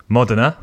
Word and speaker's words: Modena: Modena.